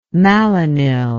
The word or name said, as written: malonyl